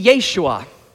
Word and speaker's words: Yeshua: And so if we were to pronounce his actual name, it would be Yeshua.